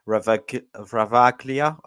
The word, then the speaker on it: Ravaglia: So Ravaglia, I think is how you pronounce it, Ravaglia, in goal, replacing Skorupski and Moro as well.